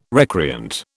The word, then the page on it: recreant: British English